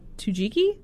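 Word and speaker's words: Tsukiji: Tujiki, I think is correct.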